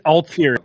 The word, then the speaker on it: Altieri: It's Altieri.